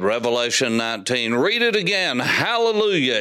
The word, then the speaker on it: hallelujah: As a matter of fact, if you open your Greek New Testament and read the Koine Greek, there's a rough breather with alleluia, which means it should be pronounced hallelujah, because hallel is the word for praise.